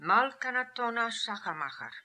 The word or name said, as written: Kmalkanatonashachermacher